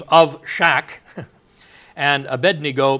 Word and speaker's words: Abednego: I don't know why we always end up pronouncing it Abednego. It's Abednego. Nego was one of the gods or something.